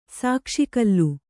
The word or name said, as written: ಸಾಕ್ಷಿಕಲ್ಲು